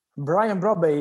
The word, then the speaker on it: Brian Brobbey: Brian Brobbey, yes, great striker.